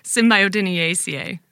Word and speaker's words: Symbiodiniaceae: Symbiodiniaceae. – That's a good Scrabble word. Symbiodiniaceae.